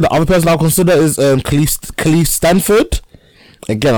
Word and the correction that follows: Lakeith Stanfield: Yeah, Lakeith Stanfield.